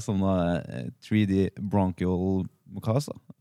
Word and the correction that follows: Mukosa: Mukosa. Mukosa. Eh, mm, riktig.